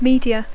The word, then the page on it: media: English